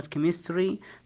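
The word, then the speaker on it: Chemistry: ومثل ما تفضلنا حرف السي والاتش ينطق حرف الكاف في حالات شاذة، مثل كلمة كيمستري معناها كيمياء، وعندنا كلمة كريستيان معناها اللي هو نصراني أو مسيحي، أو كلمة كرايست نفس كلمة كريستيان، كرايست معناها طبعا المسيح.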